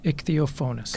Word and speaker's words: Ichthyophonus: So it's spelled ICHTHYOPHONUS, ichthyophonous.